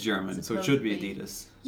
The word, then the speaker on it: Adidas: So how do you pronounce A-D-I-D-A-S? 1, 2, 3, go. – Adidas. – That was the same.